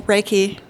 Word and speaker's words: Reiki: Well, Reiki, yeah.